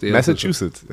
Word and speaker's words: Massachusetts: Die Tatsache, dass Cam Newton ja den Bundesstaat, und ich kann immer diesen Bundesstaat, Massachusetts, oh, ich habe ihn noch ausgegrüßt. – Massachusetts, ja. – Habe ich richtig ausgesprochen.